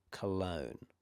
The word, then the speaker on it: cologne: Now there's one combination that you might find where it's G-N-E, G-N-E, like cologne or champagne. Now in these circumstances, the E is a magic E, so that's also silent.